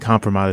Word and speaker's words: kompromat: Is that how you say it? Kompromat? – I think kompromat is kompromat. – Correct.